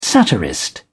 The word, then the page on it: satirist: British English